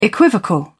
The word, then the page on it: equivocal: British English